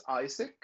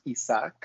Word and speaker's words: Isaac: Well, my name is Isaac. I go as Isaac. My name in Spanish is pronounced Isaac, but I find that Isaac just a little bit easier for everyone.